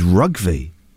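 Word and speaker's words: Rougvie: My real name is, get this right, is Rugby.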